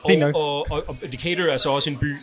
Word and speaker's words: Decatur: Ja, det udtaler så Decatur. – Decatur. Fordi man skal være altså, mid-American, ikke?